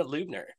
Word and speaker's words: Lubner: We're diving in with Dana Lubner. And did I pronounce that right, Dana? I'm terrible with names. Did I say Lubner right, or is it something else? – No, you nailed it.